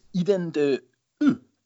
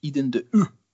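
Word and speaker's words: Eaux de Vous: So it's Eden de U. It's a silent H and a silent X, so it's just OU. So we're going with Eden de U.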